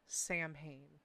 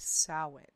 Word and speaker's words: Samhain: I know that a lot of people watch Supernatural, and I remember the episode where they called it Samhain. It is not Samhain. It is a Gaelic word, and the proper way to pronounce it is Samhain.